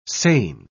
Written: seine

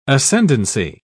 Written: ascendancy

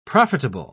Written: profitable

